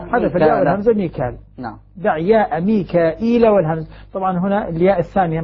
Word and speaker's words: ميكائيل: من الباقين الذين يهمزون يقول ميكائيل. إذن الباقين ميكائيل. كما نطق بها.